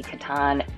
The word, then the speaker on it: Catan: Turns out the Catan heads have been saying it wrong it, as I understand it, intends to be Catan. It's a German game, so if you kind of think about that, pronunciation can also help.